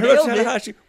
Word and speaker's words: Hiroshi Tanahashi: And they're taking on Hiroshi Tanahashi. – There you got it. Hiroshi Tanahashi.